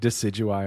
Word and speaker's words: Decidueye: I was just having flashbacks of you saying Decidueye, and I'm like, oh, okay, that's a Pokemon.